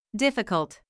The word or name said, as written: difficult